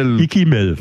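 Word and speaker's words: Ikkimel: Ikimel, so spricht man es aus, okay.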